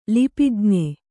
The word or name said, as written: ಲಿಪಿಜ್ಞ